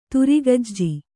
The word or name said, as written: ತುರಿಗಜ್ಜಿ